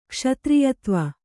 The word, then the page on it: ಕ್ಷತ್ರಿಯತ್ವ: kṣatriyatva